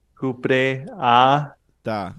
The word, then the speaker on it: Cupreata: Okay, so it's going to be cupre. – Cupre a. – Ta. – T-A. Ta agave. Cupreata agave.